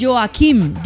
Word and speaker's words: Joachim: Dígale don Joaquim. Se escribe Joachim, pero Joaquim Clement.